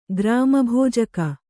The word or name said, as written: ಗ್ರಾಮಭೋಜಕ